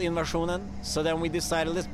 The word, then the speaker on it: INVSN: INSVN is pronounced INSVN, but it's spelled I-N-V-S-N for our listeners. Why'd you guys decide to leave out some vowels? – Well, we're a Swedish band, and we used to be called INSVN, which is a Swedish name, and it's spelled a bit, it's spelled like invasion with the E-N at the end.